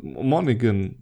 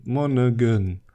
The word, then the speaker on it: Monaghan: Warte, jetzt habe ich es richtig ausgesprochen. Dominic Monaghan? Monaghan. Monaghan.